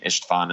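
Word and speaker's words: István: It's called St. Stephen or St. Istvan in the Hungarian pronunciation, Basilica, St. Stephen's Basilica.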